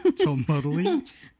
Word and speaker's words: Cholmondeley: Cholmondeley. – Cholmondeley.